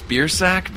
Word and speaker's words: Biersack: I think it's Biersack because that's how we pronounce it in my family. But it was one of those things where I've been told that the correct pronunciation is something else. But okay. I guess we just chose Biersack, which is possibly the ugliest way you could say it.